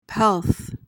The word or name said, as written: pelf